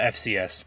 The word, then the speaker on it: FCS: They're coming up um, from the uh, FBS last year or FCS. Did I say that wrong? – Uh, FCS.